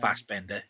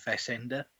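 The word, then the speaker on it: Fessenden: It's not Fassbender, it's Fassender. Fassender, Fassender.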